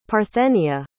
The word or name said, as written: parthenia